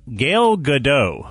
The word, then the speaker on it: Gal Gadot: Anyway, so in Today in Twitter, we're going to look at Gal Gadot. I believe that's how you pronounce Wonder Woman's name.